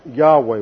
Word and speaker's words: Yahweh: And this is related to the Old Testament personal name for God, which we pronounce Yahweh. Y, Y H W H.